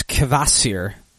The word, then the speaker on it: Kvasir: It's Dogfish Head's Kvasir. I'm assuming that K is supposed to be pronounced.